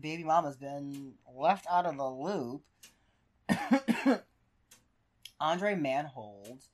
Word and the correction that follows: Andre Marhold: That can't be how you say his name. – It can't. I'm sorry, Andre Marhold.